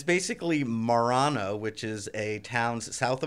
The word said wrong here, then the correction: Marino: How do you spell it? – M A R I N O. Uh, Marino.